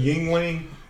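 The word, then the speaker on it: Yuengling: Anything that says Yingling. It kind of rhymes with ding-a-ling.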